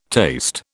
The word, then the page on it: taste: British English